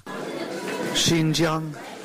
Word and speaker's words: Xinjiang: We also got a correction for Xinjiang, that place in China. – Yeah. – Xinjiang. Xinjiang. Xinjiang.